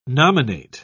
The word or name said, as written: nominate